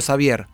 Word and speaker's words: Xavier: Digamos, es que yo, con respecto a mi nombre, ya sabemos, siempre lo hablamos, cada uno lo pronunciará como puede, y el nombre de mi disco también. Yo digo Xavier.